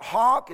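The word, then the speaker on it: hough: Thou shalt hawk, is how you pronounce that.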